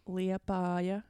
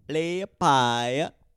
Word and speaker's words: Liepāja: Liepāja. – Liepāja.